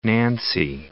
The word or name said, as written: nancy